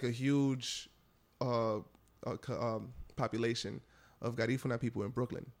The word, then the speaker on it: Garifuna: Put the accent, the emphasis on the you're putting it on the funa. Yeah. – Garifuna.